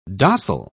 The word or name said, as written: dossal